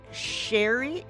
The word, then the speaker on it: Scieri: Antoinette Sherry is how you pronounce her name, but it's spelled S-C-I-E-R-I.